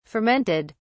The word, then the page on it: fermented: British English